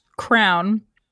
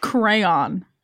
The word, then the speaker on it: crayon: I'm laughing because I was taught in school it was not pronounced crown, but it was pronounced crayon.